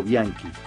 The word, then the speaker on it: Bianchi: Había nacido en 1961 como Miriam Alejandra Bianchi, o Bianchi, como se pronunciaría, ¿no?